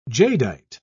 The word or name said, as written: jadeite